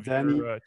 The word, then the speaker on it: Danny: Yes, so in Dutch that would be Danny.